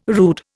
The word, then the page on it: rood: Indian